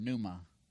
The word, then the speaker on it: pneuma: The word for wind and the word for Spirit, of course, you all know, is the same word, pneuma. Pneuma, if you want to pronounce that silent P.